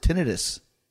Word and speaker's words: tinnitus: Is that how you pronounce it, tinnitus? – I think it's tinnitus or tinnitus. I don't think there's – both are acceptable, I believe.